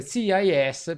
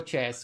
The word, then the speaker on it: CES: oh, no, oggi lo dicevano proprio oggi che si pronuncierebbe CIS, però noi italiani CIS. ci piace dirlo CES ah, perché è più divertente.